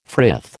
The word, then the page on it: frith: American English